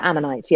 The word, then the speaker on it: Ammonite: Well, I mean, I watched the new movie, Ammonite. Is that how you say it? Ammonite? – Yeah, Ammonite. Yeah.